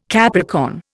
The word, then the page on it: Capricorn: Indian